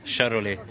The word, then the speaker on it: Charolais: Charolais. – Charbray. – Charolais. – Charbray. – Charley. – Charbray. – Charolais. – Charbray. – Charolais. – Okay, and then I'll go up the other end. – Charolais.